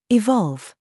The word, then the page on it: VERVE: British English